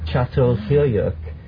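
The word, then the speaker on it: Çatalhöyük: It's actually spelled C-A-T-A-L, but it's called Chatol. Chatol Hyuk.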